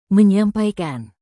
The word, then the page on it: menyampaikan: Indonesian